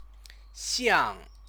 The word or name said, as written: xiang